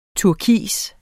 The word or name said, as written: turkis